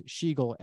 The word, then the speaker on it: Schigiel: and my last name is Schigiel S C H I G I E L.